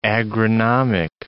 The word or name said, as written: agronomic